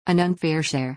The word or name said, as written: an unfair share